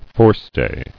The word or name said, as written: Forestay